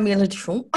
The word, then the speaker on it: Mila DeChant: So I am Mila DeChant.